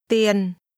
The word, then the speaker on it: tiền: ông có bao nhiêu tiền tiền Tiền. – Money is pronounced with a low falling tone.